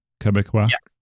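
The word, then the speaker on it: Québécois: Am I saying that properly? Quebecois? – Yeah.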